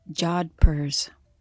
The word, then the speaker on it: jodhpurs: Actually, Jopur. Final answer. – No. – That is wrong. – What? How do you pronounce it? – Jodhpurs.